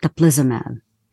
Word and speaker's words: teplizumab: So during development, the medication was called teplizumab.